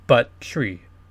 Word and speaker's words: Buttrey: B-U-T-T-R-E-Y. The way it's pronounced is Buttrey.